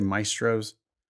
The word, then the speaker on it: Maestros: LSAT Maestros. Am I saying that right? – I'd say Maestros. – Maestros. So the AE becomes an I sound.